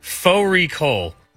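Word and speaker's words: fårikål: Faux recall, which is a mutton and cabbage. It's spelt like Fari call.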